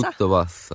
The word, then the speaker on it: tutto passa: Yy opadły emocje, tutto pasa, tak jeszcze powiem. Czy ładnie wymawiam? Tutto pasa? Tutto passa, tutto tak, tak.